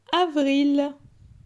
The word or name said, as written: Avril